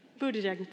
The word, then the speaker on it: Buttigieg: We have a gay man this year running for president, Pete Buttigieg. I don't know if I pronounced that right. Buttigieg, thank you.